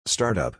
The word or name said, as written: Startup